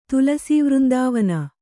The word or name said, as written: ತುಲಸೀವೃಂದಾವನ